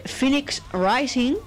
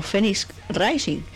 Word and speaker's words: Phoenix Rising: y es que el nombre de esta banda se pronunciaría Phoenix Rising, sería la pronunciación correcta en inglés, pero yo diría que todos los conocemos como Phoenix Rising. Es decir, el ave Fénix de toda la vida. Pero bueno, Phoenix Rising o Phoenix Rising